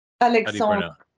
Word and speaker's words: Alexandre: So your musical partner, is it Alexander? Alexandra? – Alexandre. Yeah, Alexandre. – Alexandre. – Yes. – So Alexandre. – Perfect. – I love that D-R-E at the end. Alexandre. Not quite pronouncing it all.